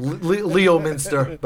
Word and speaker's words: Leominster: And for those of you who are listening that aren't from Massachusetts, Leominster is spelled L-E-O. – Oh, yeah, yeah. – M-E-N-S-T-E-R. – Leominster. But it's pronounced Leominster, yeah. – Yeah, Massachusetts, they just jettison vowels all over the place.